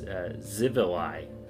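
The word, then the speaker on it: Xivilai: Also, I think I was mispronouncing that word. It's Zivali.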